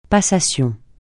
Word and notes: passation: francais

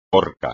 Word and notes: orca: espagnol

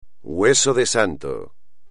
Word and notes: hueso de santo: espagnol

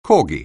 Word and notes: corgi: anglais

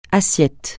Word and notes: assiette: francais

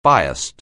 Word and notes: biased: anglais

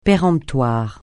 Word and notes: péremptoire: francais

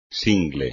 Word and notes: single: espagnol

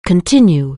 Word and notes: continue: anglais

